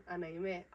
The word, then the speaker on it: Anayme: And I would tell them Anaime.